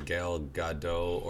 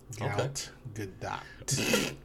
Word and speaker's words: Gal Gadot: Ugh, the actress like showed Jimmy Kimmel how to correctly say her name, telling him to emphasize the T a bit more. – Mm. Gal Gadot.